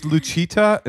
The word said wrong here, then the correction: Lucia: is that how you say it? Is that her name? Lucia.